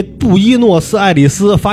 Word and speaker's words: 布宜诺斯艾利斯: 读读的特别好，你这布宜诺斯艾利斯发音特别标准。